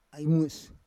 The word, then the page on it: ai mues: Roon